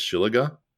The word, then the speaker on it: shillelagh: Shillelagh. Yeah, that would be clearly gold. – I think the proper pronunciation is Shillaga.